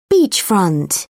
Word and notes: beachfront: British English

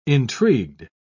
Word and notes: intrigued: British English

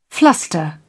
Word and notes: fluster: British English

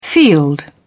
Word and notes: field: British English

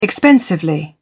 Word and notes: expensively: British English

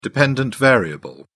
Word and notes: dependent variable: British English

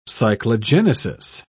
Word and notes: cyclogenesis: British English

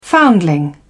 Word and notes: foundling: British English

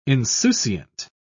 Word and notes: insouciant: British English

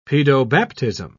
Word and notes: paedobaptism: British English

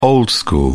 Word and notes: old school: British English

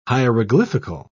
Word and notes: hieroglyphical: British English

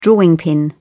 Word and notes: drawing pin: British English

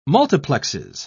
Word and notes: multiplexes: British English

